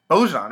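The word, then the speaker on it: Bojan Bogdanović: The biggest addition, though, on that team, I hope I say his name right, Bojan Bogdanovic. Bojan, right? That's right.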